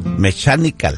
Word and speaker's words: mechanical: Hay que seguir la nada más seguir la página, arroba Mondragón Mecánica, se escribe M-E-C... H-A-N-I-A-L. ¿Lo dije bien? – Mechanical. – Mechanical, ¿cómo? Así es, mechanical. – Pero no mecánico, es mechanical. – Es Mondragón y luego M-E-C-H-A-N-I-C-A-L, mechanical. Pero en inglés es mechanical.